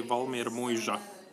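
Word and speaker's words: Valmiermuiža: We, we call it uh, Valmermuisa, but yeah, uh, how do you say it uh, correctly? we say correctly Valmermuisa. – Valmermuisa. – So the Z is J. – Yeah.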